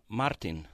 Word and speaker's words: Martin: Martin. – Martin. – If you can roll your R.